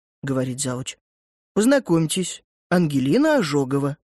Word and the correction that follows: Ожегова: «Ожегова», — поправляет из коридора женщина в очках. «Ударение на первый слог, как толковый словарь».